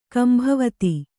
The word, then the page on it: ಕಂಬಾವತಿ: kambāvati